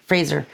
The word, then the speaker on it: Fraser: Fraser.